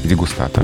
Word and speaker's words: дегустатор: Оно стало еще больше нам привычно, и как это часто бывает со многими словами, осваиваясь в языке, оно изменило твердое произношение на мягкое. Ну так, смягчилось, чтобы его признали, да? То есть сейчас, да, предпочтительно дегустация дегустатор.